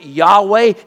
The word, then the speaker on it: Yahweh: And he stamped it, and we pronounce it Yahweh.